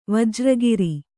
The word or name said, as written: ವಜ್ರಗಿರಿ